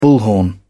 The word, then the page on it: bullhorn: British English